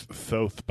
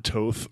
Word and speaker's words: Thoth: And it's Tote's cool. – It is Tote's awesome. It can also be pronounced Thoth, by the way. – That's true. – But not Toth or Thote.